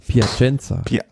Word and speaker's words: Piacenza: Von Plaisson, nee, wie spricht man das aus? – Piacenza. – Hä? Aber warum? Aber dann ist es falsch geschrieben. – Das sind natürlich die französischen Namen, die du auf der Tour de France-Seite hast.